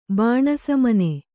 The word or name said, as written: ಬಾಣಸಮನೆ